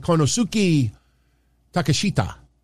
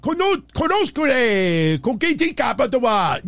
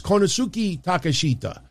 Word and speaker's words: Konosuke Takeshita: Fuck you, Excalibur, and I'm a big fan of Excalibur, but Konosuke Koketika Patawa. Nobody pronounces it like that. Just like nobody says Ray Phoenix. No, it's Ray Phoenix Konosuke Takeshita.